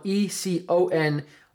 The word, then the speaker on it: Econome: Economy is spelled E-C-O-N-O-M-E. So like econ-o-m-e, me, like me, I'm a person.